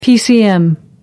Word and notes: pcm: American English

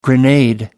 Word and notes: grenade: American English

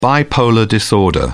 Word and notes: bipolar disorder: British English

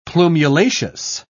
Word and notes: plumulaceous: British English

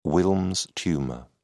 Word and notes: wilms tumour: British English

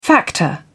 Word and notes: factor: British English

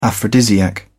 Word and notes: aphrodisiac: British English

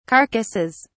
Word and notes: carcasses: British English

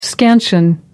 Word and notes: scansion: American English

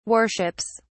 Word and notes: worships: British English

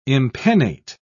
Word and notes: impennate: British English

